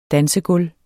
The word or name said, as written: dansegulv